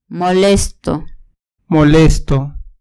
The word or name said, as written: molesto